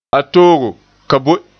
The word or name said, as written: toogo